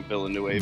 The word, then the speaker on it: Villeneuve: Yeah, that's actually why I dove in, because Dennis, I don't know how to say his last name, Villeneuve.